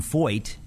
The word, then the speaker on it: Feucht: And part of the reason I say this is because this guy, Sean Foyt, it's spelled F-E-U-C-H-T. He was on this program, as you know. But Sean Foyt spent about 2 decades going into Afghanistan, Iraq, into the ISIS zones when it was controlled by ISIS.